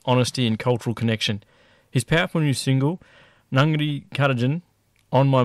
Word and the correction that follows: Nungkatajan: How do you pronounce it? It's Nungani? – Yeah, pretty close, brother. It's Nungkatajan. – Nungkatajan.